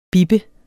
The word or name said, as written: bip